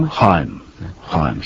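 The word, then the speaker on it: Heim: How do you pronounce the man's name? Michael Henry Haim? – Haim, his name.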